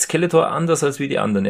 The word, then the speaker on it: Skeletor: Francis, der sagt nämlich immer Skeletor. Also er betont das erste E so, er betont die erste Silbe, das erste E so ein bisschen länger, so ganz klassische deutsche Betonung.